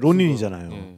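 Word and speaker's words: 浪人: 그러니까 론인이 정말로 그 일본 말의 낭인을 일본 발음으로 그쵸, 하면은 이제 론인이잖아요.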